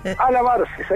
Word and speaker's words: Albareces: Me pareció o pronunciaste muy mal mi apellido. – Albacerces. – Albacerces, ahí está.